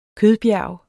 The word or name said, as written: kødbjerg